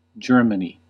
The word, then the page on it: Saksa: /ˈsɑk.sɑ/